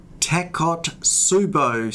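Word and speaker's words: Takotsubo: Today I'm going to discuss a condition called Takot-Subo syndrome. Now that's a heck of a mouthful. It's spelled T-A-K-O, Tako, T-Subo, T-S-U-B-O. It's a Japanese word and I'll tell you more about it in a second.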